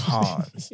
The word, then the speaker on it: Hans: And some people are like, okay, Hans. I'm like, it's Hans. It's like you have to take your time to say my name.